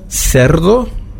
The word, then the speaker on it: cerdo: Cerdo, das wäre CE.